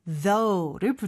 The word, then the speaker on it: though: Thanks for asking까지만 얘기하면 물어봐줘서 감사합니다인데 그래도라는 느낌을 살리고 싶으면 맨 뒤에 though를 붙여주면 돼요. though는 스펠링이 조금 독특해요. – Right, it's thoughg. – 마지막 gh는 발음을 여기서 하지 않죠, 그렇죠? though 이상합니다. – O-U-G-H, you just have to memorize the pronunciation because there are 음흠.